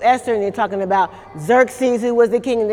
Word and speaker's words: Xerxes: Xerxes. – See, that's good to say because it starts with an X, and you'd be like, how do I say that? Okay, Xerxes, all right?